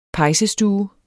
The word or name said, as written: pejsestue